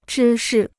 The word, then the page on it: 芝士: zhī shì